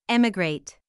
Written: emigrate

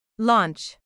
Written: launch

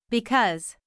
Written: because

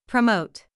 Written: promote

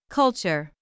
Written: culture

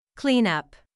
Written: clean up

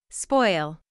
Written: spoil

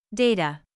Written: data

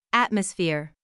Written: atmosphere

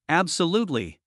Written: absolutely

